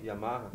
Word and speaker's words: Yamaha: Aqui na minha cidade só tem Honda e Imarra. Imarra. Como é que fala? – Não sei. – Yamaha. Yamaha. Alguma recomendação?